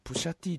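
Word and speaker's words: Pusha T: 前田と、あの、プッシャ T がね、ちょっと似てんのよ。 – プッシャ T どういう顔だったっけ？ – あのね、プッシャ T が、ちょっと、スンっていう顔してる時の、顔が、ちょっとね、あの、前田に似てんだよね。プッシャ T っていうか、これ、プッシャ T って読むんですね。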